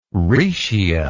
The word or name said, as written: rhaetia